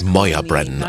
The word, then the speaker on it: Moya Brennan: Ja, man spricht meinen Namen wirklich Moya Brennan aus. Ich habe die Schreibweise zu Moya, M-O-Y-A, geändert, weil alle meinen gälischen Namen falsch aussprachen, erzählt Moya. Deshalb schreibe ich es jetzt so, wie man es ausspricht.